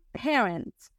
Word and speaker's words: parent: P-A-R-E-N-T. Y aquí lo más importante es pronunciar la R suavecita y la T final. Parent, y obvio meterle pa a la p, ¿ok? Parent, parent, parent.